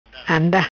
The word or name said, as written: Anda